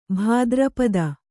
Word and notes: ಭಾದ್ರಪದೆ: Kannada

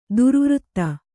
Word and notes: ದುರ್ವೃತ್ತೆ: durvřtte

